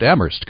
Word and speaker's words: Amherst: By the way, Professor Ellis mentioned that he also taught at Amherst College, which is up the road from Mount Holyoke, and is my brother's alma mater. Well, its pronunciation is also a shibboleth. The H is silent.